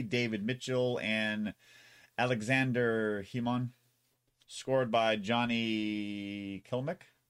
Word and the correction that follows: Klimek: Kilmick? How do you pronounce that? – Well, if you spelled it right, it says Klimek. – Oh, there you go. Klimek.